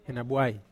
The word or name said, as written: henabuai